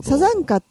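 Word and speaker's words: 山茶花: これはね、要するに椿っていうのはサザンカのことなんですね、はい、サザンカって山に茶に花と書いてサザンカと呼びます。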